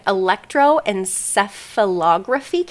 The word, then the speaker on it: electroencephalography: So an electroencephalography cap. – Yep. – Did I say that correctly? Yeah, you did.